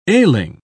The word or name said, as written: ailing